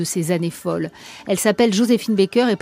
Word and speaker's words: Joséphine Baker: Oui, car c'est avec la prononciation française qu'elle voulait que l'on prononce donc son nom, Joséphine Baker, star du musical naturalisé française en 1937.